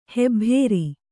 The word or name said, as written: ಹೆಬ್ಭೇರಿ